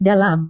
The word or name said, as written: dalam